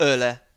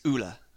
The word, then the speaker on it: Ole: So it's not Ole, it's not Ole. It's Ulla.